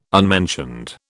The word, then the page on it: unmentioned: British English